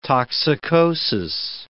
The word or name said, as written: toxicosis